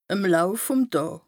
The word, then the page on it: ìm Lauf vùm Dàà: Bas-Rhin; Strasbourg